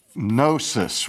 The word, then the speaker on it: gnosis: Now, when we spell Gnosticism, we spell it with a G, G-N-O-S-T-I-C-I-S-M. And Gnosticism comes from the Greek word gnosis, which is also spelled with a silent G, G-N-O-S-I-S, which is simply the Greek word for knowledge.